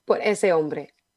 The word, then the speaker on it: Por ese hombre: Por este hombre. Did I say it right this time? – Por ese hombre. – Por ese hombre. Oh, that's close. – Ese means him.